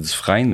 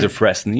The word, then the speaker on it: Dufresne: T'sais, c'est Dufresne, mais quand tu prends en anglais, c'est Dufresne.